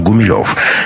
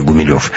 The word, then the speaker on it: Гумилев: Кстати, знаете ли вы, что в те времена ударение в этой фамилии ставили на первый слог, поскольку фамилия эта происходила от латинского слова «humilis» — «смиренный». – Между тем, мой далекий предок Николай Гумилев не слишком жаловал свою фамилию в юности. И от всех требовал, чтобы ударение ставили на третьем слоге.